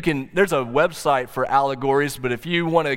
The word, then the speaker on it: Owlegories: One of those shows is called Owlegories, but it's spelled O W L E G O R I E S, Owlegories.